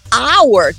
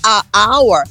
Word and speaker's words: hour: Porém, quando você vai dizer hour, que também começa com H, não vai ser a hour, porque você, esse H é mudo, então você vai dizer an hour.